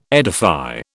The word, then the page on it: edify: British English